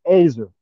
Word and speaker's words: ezer: Ezer, or it's pronounced Azer. It's spelled E-Z-E-R.